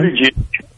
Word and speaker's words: Buttigieg: But when I look at the folks immediately behind him, Bernie Sanders, Pocahontas, Buttigieg, how do you say his name? – Yes, dude. – Buttigieg.